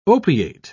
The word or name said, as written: opiate